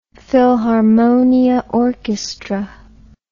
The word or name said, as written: Philharmonia Orchestra